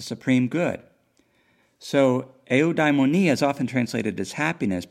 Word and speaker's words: eudaimonia: but it's eudaimonia, eudaimonia, E-U-D-A-I-M-O-N-I-A, and it's traditionally translated as happiness.